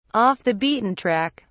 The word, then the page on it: off the beaten track: British English